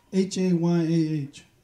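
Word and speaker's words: Hayah (הָיָה): This word was is the word Hayah in the Hebrew, H A Y A H, okay? And it's pronounced Hayah.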